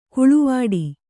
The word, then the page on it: ಕಾಳವಟ್ಟ: kāḷavaṭṭa